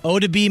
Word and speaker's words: Oddibe: O-D-D-I-B-E. – Odibe. – Odibe McDowell.